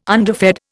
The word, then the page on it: underfed: Indian